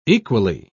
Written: equally

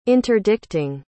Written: interdicting

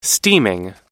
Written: steaming

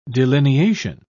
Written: delineation

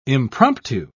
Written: impromptu

